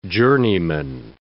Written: journeyman

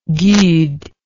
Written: gude

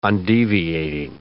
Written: undeviating

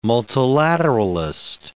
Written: multilateralist

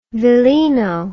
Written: villino

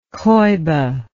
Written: coiba

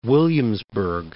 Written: Williamsburg

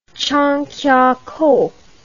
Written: Changkiakow

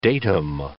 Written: datum

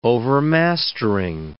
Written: overmastering

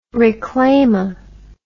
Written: reclama

